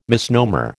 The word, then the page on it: misnomer: American English